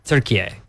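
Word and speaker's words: Türkiye: Pronounce it turkey A. – Turkey A. – It's pronounced turkey A.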